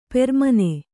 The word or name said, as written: ಪೆರ್ಮನೆ